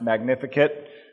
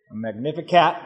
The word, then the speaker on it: Magnificat: And Elizabeth told me I did, she's studying Latin, and she told me I did pronounce Magnificat. Magnificat, I did pronounce that correctly.